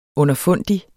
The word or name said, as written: underfundig